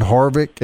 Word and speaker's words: Harvick: If I mispronounce his name correct, but Kevin Harvick, is that not right? Like the main guy right now? – That's right.